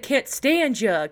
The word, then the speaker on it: Constantia: Constantia. – Oh, so hard. I was like, what the? What? And it was like, yeah, it's pronounced like can't stand ya.